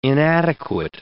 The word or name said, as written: inadequate